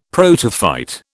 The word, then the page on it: protophyte: British English